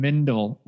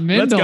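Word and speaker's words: Wendle: I do want to see if he nails Zunino, Kittredge, and Wendell. Hey, not Mendel.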